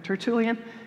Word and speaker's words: Tertullian: And it was actually Tertullian, Father, that's how you say it, right? Tertullian.